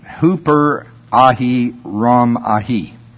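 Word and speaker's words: Hooper Ahi Ram Ahi (ὑπεραίρομαι): And it's Strong's number G5229, pronounced, here's a dandy for you, Hooper Ahi Ram Ahi. Sounds like Cherokee or something, but it's the middle voice.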